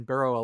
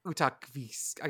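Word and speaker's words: Utqiagvik: I'm going to try to go to a game in Burrow, Alaska. Or not Burrow now. It's Utakvisk. I got to pronounce by the pronunciation.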